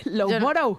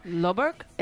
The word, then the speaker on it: Loughborough: Loughburg. Esta es tuya. – A ver, dale. Dale esta. – Loughborough. – ¿Pero Loughborough. Dónde está la F ahí? Venga ya.